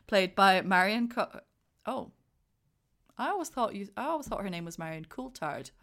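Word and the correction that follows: Marion Cotillard: Have I said her name wrong? – Marianne Cotillard. – Cotillard. Thank you. – Yeah. – Maybe. – Marianne. – We'll just Cotillard. – Go with that and apologize for my inability to be able to pronounce this name correctly. – Double L in French is a yeah.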